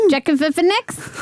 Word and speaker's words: Joaquin Phoenix: How did you say his name? – Joaquin Phoenix. – That'll do. That's correct.